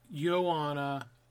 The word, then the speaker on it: Joanna: It's like Joanna, like the J silent.